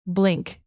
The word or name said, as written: blink